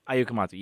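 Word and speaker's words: Ayao Komatsu: Ayo Komatsu, yes.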